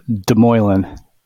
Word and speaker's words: Demoulin: It was close. Demoulin. It's the English pronunciation.